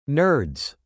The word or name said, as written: nerds